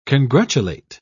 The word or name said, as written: congratulate